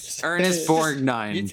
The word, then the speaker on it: Borgnine: That you get Ernest Borgnine, Ernest Borg, whatever the fuck, man, Borgnine. – Say it again. – Ernest Borgnine. – It's okay to pronounce the G in this case. – Borgnine.